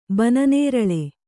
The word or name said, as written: ಬನನೇರಳೆ